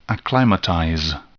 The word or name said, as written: acclimatize